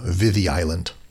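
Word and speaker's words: Viðey: This is often spelled Vidi Island, V-I-D-E-Y, but it has one of those thorn D's in the middle, so it's pronounced as the Vidi Island.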